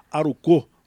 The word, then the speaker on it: Aruko: O nome do time, quer dizer, um nome japonês e tal, escreve-se Aruko, mas se pronuncia Aruko com a última sílaba mais forte.